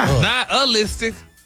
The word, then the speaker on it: nihilistic: Stop putting the ooh in there. – Nihilistic? – There you go.